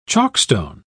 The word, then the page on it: chalkstone: British English